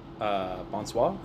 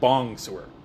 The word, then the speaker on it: bonsoir: I'm assuming he meant like bonsoir? Or like that. Bonsoir. – Yeah. I can't believe I pronounced that correctly. But you know what? Well, if I mispronounced it, I wouldn't have said bongsoir.